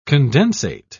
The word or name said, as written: condensate